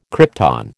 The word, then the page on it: krypton: American English